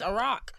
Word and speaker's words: Iraq: It's Iraq. – That don't even sound right. – It's Iraq. – I've been saying Iraq. – Because our fucking president was saying it wrong the whole time.